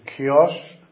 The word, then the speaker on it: Chios: and also came to Chios, that's spelled with a Greek letter that's like an X, and the same as Christos, for example,